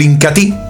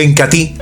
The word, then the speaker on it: Incatea: como lo es el Incatí. Se escribe Incatea, pero se pronuncia Incatí.